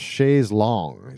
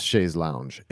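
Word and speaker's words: Chaise Longue: And they're from the UK, and the song is called Chaise Long, is how they pronounce it. Chaise Long. – Chaise Long. – Instead of Chaise Lounge.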